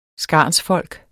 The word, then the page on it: skarnsfolk: ˈsgɑˀns-